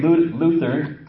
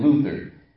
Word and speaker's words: Luther: You're going to hear me say Luther and Luther. Because the German way of pronouncing Luther is Luther. Okay, so if you hear me say Luther, I'm not making a mistake. I'm saying it in the way that the German tongue or the German people pronounce Luther.